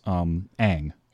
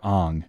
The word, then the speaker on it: Aang: I mean, Noah Ringer plays Aang, or Aang, as they pronounce it in the movie.